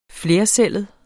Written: flercellet

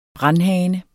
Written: brandhane

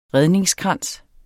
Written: redningskrans

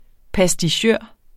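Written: pastichør

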